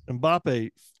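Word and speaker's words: Mbappé: Mbappe, 3 goals.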